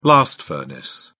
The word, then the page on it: blast furnace: British English